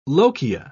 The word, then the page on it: lochia: British English